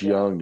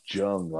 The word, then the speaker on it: Jung: I want Josh Jung. I like that. I like the idea of like having that hard J there to Jung. – Jung. But he's going to be Josh Young, apparently.